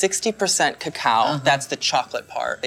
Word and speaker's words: cacao: You know, they were talking about that cacao. – Mm-hmm. – Is that how, am I saying it right? – You are, so that refers to, cacao refers to the bean before it's been hulled and fermented.